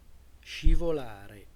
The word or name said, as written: scivolare